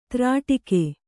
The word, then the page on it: ತ್ರಾಟಕ: kannada